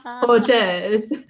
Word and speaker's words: hors d'oeuvres: So when customers mispronounce dishes like mixed hors d'oeuvres as they say, say it. – Because hoobie doobies.